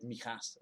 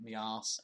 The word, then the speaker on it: Mijas: and it was in a place in Spain called Mijas, and he kept pronouncing it Mias, and that's all I really remember about it, but it was very funny.